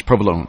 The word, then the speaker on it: provolone: The COG centers, that's Trinidad and Walsenburg, a turkey provolone sub sandwich, or it's provolone, I guess, provolone. Yeah, you don't pronounce the E.